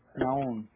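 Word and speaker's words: Nahum: Naon.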